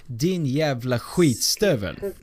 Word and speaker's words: Din jävla skitstövel: The right pronunciation is din yavla skitstovel.